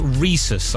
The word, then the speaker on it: rhesus: This is pronounced rhesus, I've been reliably informed.